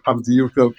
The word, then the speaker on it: Avdiivka: The big news this week, as you've already alluded to, was the fall of Avdiivka. You'll have to excuse my pronunciation. – Well said, Avdiivka, perfect pronunciation.